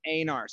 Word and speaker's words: Einar: You know, there's actually, my family actually pronounces it Einar, sort of like reindeer, right? – Einar, okay.